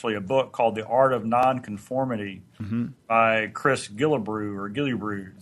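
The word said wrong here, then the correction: Guillebeau: Is that how you say his name? – Gillibo, I think. I think it's Gillibo.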